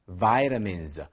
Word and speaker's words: vitamin: 혹은 vitamins, 비타민 같은 거죠. 자, 우리말로는 비타민이라고 하지만 영어로는 vitamin 이렇게 발음합니다.